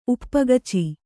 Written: ಉಪ್ಪಗಚಿ

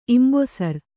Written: ಇಂಬೊಸರ್

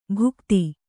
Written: ಭುಕ್ತಿ

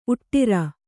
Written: ಉಟ್ಟಿರ

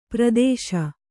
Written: ಪರದೇಶಿ

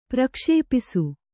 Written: ಪ್ರಕ್ಷೇಪಿಸು

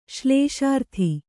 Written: ಶ್ಲೇಷಾರ್ಥಿ

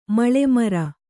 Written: ಮಳೆಮರ

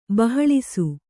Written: ಬಹಳಿಸು

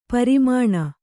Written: ಪೆರ್ಮನೆ

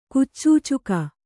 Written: ಕುಚಚೂಚುಕ

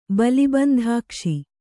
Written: ಬಲಿಬಂಧಾಕ್ಷಿ

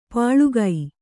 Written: ಪಳಕ

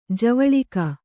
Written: ಜವಳಿಕ

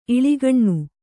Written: ಇಳಿಗಣ್ಣು

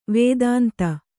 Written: ವದಂತಿ

